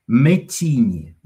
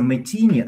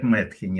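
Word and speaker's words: Metheny: Еще раз, это такой гитарист американский, которого зовут Пэт, P-A-T, Мэттини. Вот говорится Мэттини, а пишется Мэтхени. Вот если по буквам, Мэтхени, N-Y в конце. Пэт Мэтхени. И мы даже думали, что он Пэт Мэтхени, а потом однажды услышали по радио Пэт Мэттини.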